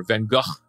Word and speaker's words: Van Gogh: Jill, speaking of Amsterdam, a certain famous birthday for an artist named Van Gogh, or Van Gogh, as they pronounce it, and a big birthday for one of our favorite game shows, Jeopardy.